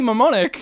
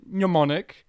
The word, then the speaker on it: Mnemonic: Oh, Johnny Mnemonic. I pronounce it with an M when there shouldn't be one. – Well, it starts with one, so it should be, right? – Yeah. So, Johnny Mnemonic, the classic Keanu Reeves movie from what year? – 1995?